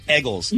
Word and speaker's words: Eagles: By the way, it's Eggles, not Eagles. It's Eggles, okay? You got to say it like a Philadelphian.